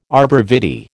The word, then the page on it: arbor vitae: American English